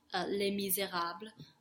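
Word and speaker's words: Les Misérables: Les Mis is short for Les Miserables, or if you're a snob and you have to pronounce it the French way, Les Miserables.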